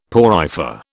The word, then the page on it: porifer: British English